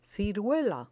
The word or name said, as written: ciruela